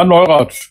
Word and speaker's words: Neurath: Paul Neurath oder? Ja, Neurath, genau. Okay, war schon richtig. – Also so richtig mit TH, ganz ordentlich. Also, also, also nicht deutsch. Neurath.